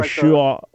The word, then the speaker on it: Yeshua: For sure, yeah, Yeshua would have been the pronunciation of Jesus' name, and then in Greek, Jesus.